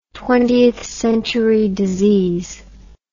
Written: Twentieth Amendment

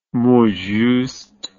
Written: mot juste

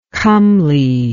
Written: comeliness

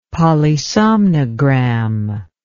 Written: polysomnogram

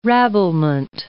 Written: rabblement

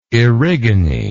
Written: Erigone

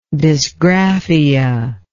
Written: dysgraphia

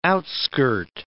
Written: outskirt

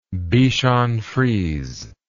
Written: bichonfrise

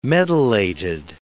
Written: medullated

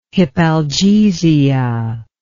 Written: hypalgesia

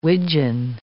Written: widgeon

